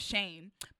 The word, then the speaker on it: Chayne: My middle name is Shane, but it's spelled C-H-A-Y-N-E.